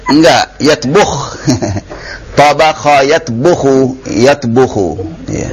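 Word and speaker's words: yatbuhu (يطبخ): Nah, ini memang sering kata tabakha ini sering salah pengucapan ya. Mungkin sudah terbiasa ya. Iya, bukan tabakha yatbahu. Tabakha yatbuhu. Yatbuh. Bukan yatbah.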